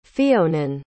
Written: Fionan

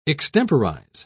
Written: extemporize

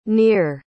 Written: nir

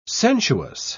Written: sensuous

